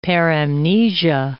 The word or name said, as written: paramnesia